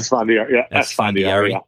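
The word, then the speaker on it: Esfandiari: We talked about one, Antonio Estefandari. Is that how you say his name? – Estefandari.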